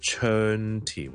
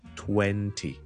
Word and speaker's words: twenty: 20 我都香港人总会读成呢 twenty 或者 twenty， okay no it's twenty twenty twenty twenty，读 a 读 when 先，好似我哋读 Wendy 人名个 Wendy，但系我哋系前面加个好轻嘅，因为前面就 twenty twenty， so the total is 120 please， okay the total is 120 please。